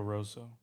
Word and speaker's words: Rosso: Uh, Porco Rosso, Russo, 1992. Porco Rosso. – Yes, 1992.